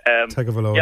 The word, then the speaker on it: Tagovailoa: Tagovailoa, yeah.